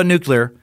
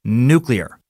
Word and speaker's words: nuclear: And just so you know, I know the correct pronunciation is new. Nuclear. Nuclear is another way I say it sometimes, and just know that variant is accepted, as I pointed out in the past by Merriam-Webster. So calm the fuck down, pronunciation Nazis.